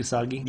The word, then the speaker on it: Usagi: That's how you say it, Usagi? – Yeah.